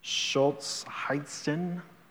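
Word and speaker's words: Solzhenitsyn: I think his name is pronounced Alexander Schultz-Heitzen. That's it.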